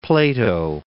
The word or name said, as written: Plato